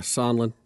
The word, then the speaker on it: Sondland: Supposedly, this is do you say his name Sondland? – Yeah, Sondland.